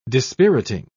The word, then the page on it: dispiriting: British English